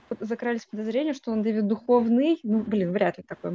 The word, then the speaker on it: Духовный: Как его имя произносится? Духовный или Духовный? – Я вот без и краткого в конце, то есть я просто Духовный говорю.